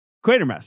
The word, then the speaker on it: Quatermass: I feel like I'm be the pedant here, but it's Quatermass, I believe. – Quatermass, sorry. Dr. Quatermass.